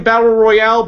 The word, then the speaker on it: Battle Royal: It's they're pronouncing it Battle Royale, but they're spelling it Battle Royal, which is kind of weird.